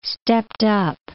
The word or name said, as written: stepped-up